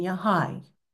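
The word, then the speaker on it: Ya Hayy: It is Yanur, which is N-U-R, but pronounced N-O-O-R. Ya'alim, spelled A-L-I-M, but pronounced A-L-E-E-M, and Yahai. Hayy, but with a long I and sort of an emphasis on the A at the end.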